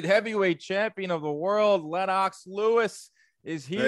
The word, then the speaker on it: Lennox: Don't call me Lennox Lewis. And we were just talking before. That's the right way to pronounce it, because now we can just squash this now once and for all, because Twitter just says it over and over.